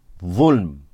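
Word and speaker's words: ظلم: In Arabic it is spelt Va lam mim, zulm. Okay? In English, you can write it out however you think it sounds. T-H-U-L-M, whatever. It's okay. As long as you're saying it in the ballpark. What is zulm?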